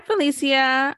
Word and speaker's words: Felecia: Hi, Felecia.